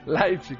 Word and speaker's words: Leipzig: Apreciable la pronunciación también de Leipzig, sí, gracias a quienes nos han dicho cómo se pronuncia Leipzig. – Leipzig, no se vayan a equivocar, 3-2 al Porto.